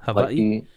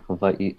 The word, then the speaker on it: Hawaii: I've heard Hawaii, or that's the, if you're saying, I believe if you're saying it in like a Native Hawaiian aspect, it is Hawaii because the W is pronounced as a V. – Okay, that makes sense. – I know like local people would say Hawaii too. So those are like two accepted ways to say it.